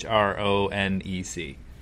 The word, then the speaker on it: Hronec: And I'm going to roll with Unlock Your Macro Type by Christine Hronek, might be how you pronounce her last name. I don't know. It's Hronec.